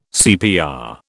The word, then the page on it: CPR: British English